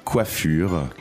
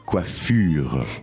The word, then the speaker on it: coiffure: So, the pronunciation, what's the difference between that and a hairstylist? – Donc, on a coiffeur. – And that's a hairstylist. – Et puis, coiffure. – Coiffure. And that's a haircut.